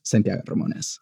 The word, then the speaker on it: Santiago Ramones: It's always a weird thing of like, if I'm saying my name so that people can hear and understand my name, I will say Santiago Ramones. Whenever I'm saying it for myself, it is Santiago Ramones. Because okay. That is how it's pronounced. But I'm not going to expect anyone to try and work their tongue in ways that they're not used to.